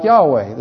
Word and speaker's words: Yahweh: But the Hebrew word is something like Yahweh. There's some doubt about how it's pronounced, but that's the way most people say it, so that's how we'll say it.